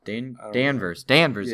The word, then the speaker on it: Danvers: Carol Danvers? Danvers, yeah, how you pronounce it.